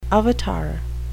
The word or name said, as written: avatar